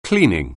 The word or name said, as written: cleaning